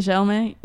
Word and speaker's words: Chalamet: That's how you pronounce his last name? – Yeah. – How did you think it was? – I don't know. I thought it was, it's Chalamet. I thought it was. – Chalamet. – I thought it was like, let me see how it's spelled.